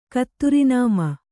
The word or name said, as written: ಕತ್ತುರಿನಾಮ